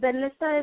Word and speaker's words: Benlysta: I think it's Benlysta. Am I pronouncing that correctly? – Correct. – Benlysta